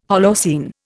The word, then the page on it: Holocene: Indian